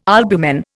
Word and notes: albumen: Indian

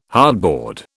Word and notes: hardboard: British English

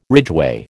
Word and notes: ridgeway: American English

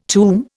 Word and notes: tomb: Indian

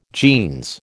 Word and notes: jeans: American English

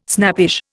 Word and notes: snappish: Indian

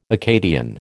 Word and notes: Acadian: American English

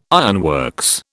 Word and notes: ironworks: British English

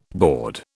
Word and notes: board: British English

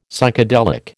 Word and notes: Psychedelic: American English

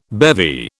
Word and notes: bevy: British English

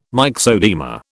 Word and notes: myxoedema: British English

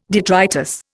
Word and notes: detritus: Indian

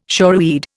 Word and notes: shoreweed: Indian

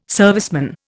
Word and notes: serviceman: Indian

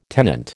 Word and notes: tenant: American English

